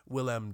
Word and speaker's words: Willem: He just has that villainous face, William Dafoe. – Yeah. – Excuse me, Willem Dafoe. – Okay. Is it like the French pronunciation? – It's Willem. Willem.